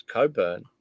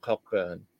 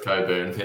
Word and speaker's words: Cockburn: It's Coburn, not Cockburn. – That's correct. It's Coburn. Yeah. – So you don't pronounce.